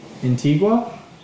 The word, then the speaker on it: Antigua: Antigua, in Barbuda, I'm pretty sure.